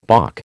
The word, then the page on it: balk: American English